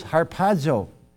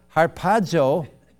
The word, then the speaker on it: harpazo: But anyway, the Greek word is harpazo. Did I pronounce that right? Thank you.